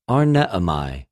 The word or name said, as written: ἀρνεομαι